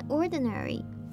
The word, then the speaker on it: ordinary: 那我们进到发音的部分，首先我们来看 ordinary 这个字的重音在第一音节，第二音节的那个 i 我们是发弱化母音 a 的声音。那第三个音节的母音 a 我们是发短母音 a，所以这个字念成 ordinary。